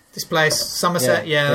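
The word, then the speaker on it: Summerset: Somerset. – It's spelled differently. – Like summer.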